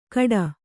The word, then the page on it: ಕಡ: kaḍa